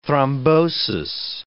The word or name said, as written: thrombosis